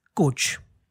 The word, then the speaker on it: Kocs: The village in Hungary is called as K-O-C-S, pronounced as coach.